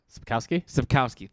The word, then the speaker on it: Sapkowski: Sapkowski.